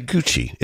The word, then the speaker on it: Giudice: We'll say Gucci. It's spelled G-I-U-D-I-C-E. – Yeah. I think that's Gucci.